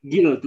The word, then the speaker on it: Gil: Gil, tulisannya G G I L itu ya.